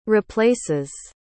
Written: replaces

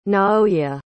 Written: naoya

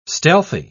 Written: stealthy

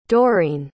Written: Dorene

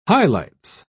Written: highlights

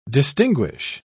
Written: distinguish

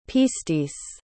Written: pistis

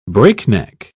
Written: breakneck

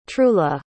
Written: trula

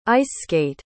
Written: ice-skate

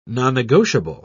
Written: nonnegotiable

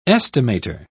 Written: estimator

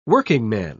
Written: workingman

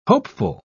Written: hopeful

